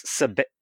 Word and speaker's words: Seabit: I think she says that it's Seabit, like it's Seabit, like ribbit.